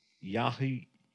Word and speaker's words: Yahya: His name is I don't want to pronounce it wrong, but Yahya. It's Y-A-H-Y-A.